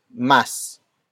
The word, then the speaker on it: mas: But first, we have mas. Mas. Mas. Mas is spelled as M-A-S.